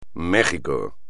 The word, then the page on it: Ciudad de México: espagnol